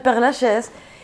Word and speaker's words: Père Lachaise: Desde el día uno, porque yo ya había visitado hace muchísimos años el Père Lachaise y me había llamado la atención.